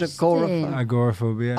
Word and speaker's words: agoraphobia: What's that called? – Agoraphobia. – Oh, agoraphobia, yeah.